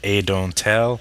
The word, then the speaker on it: Adonthell: Adontal, it's spelled A-D-O-N-T-H-E-L-L, but according to the fact, you can pronounce it any way you want, but some of the recommended suggestions are Adontal, and the H is silent in there.